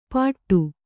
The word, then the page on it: ಪಟ್ಟು: kannada